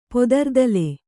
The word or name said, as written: ಪೊದರ್ದಲೆ